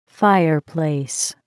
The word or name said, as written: fireplace